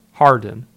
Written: Hardin

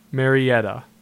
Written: Mariettia

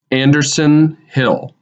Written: Anderson Hill

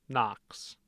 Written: Knox

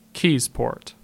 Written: Keyesport

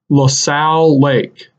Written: LaSalle Lake

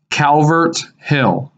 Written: Calvert Hill

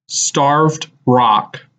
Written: Starved Rock

